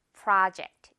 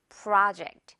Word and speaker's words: project: 하지만 영어 발음은 project입니다. 아, 이렇게 입 크게 벌려서요. project. 이렇게 발음해줘야 맞아요. 예문 한번 볼까요? 이건 어마어마하게 큰 프로젝트야. This is a huge project.